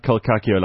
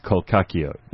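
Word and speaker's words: Col'Cacchio: In Cape Town, we pronounce Colcaccio as Colcaccio and not Colcaccio. Can you find out what the real way is? I don't want to sound like a kook. Well, Rob, we actually spoke to the guy who started Colcaccio, and he says it's Colcaccio.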